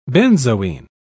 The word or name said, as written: benzoin